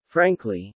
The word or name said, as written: frankly